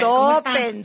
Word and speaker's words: Stoppen: Lamar Gator, que es como la conocen en redes sociales, mejor conocida por sus hijos como Valeria Stupen, Stoppen, Stoppen. Siempre la discusión de cómo se pronuncia tu apellido, hija. – Stoppen, mamá, Stoppen. – Stoppen, Stoppen.